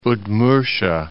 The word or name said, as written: Udmurtia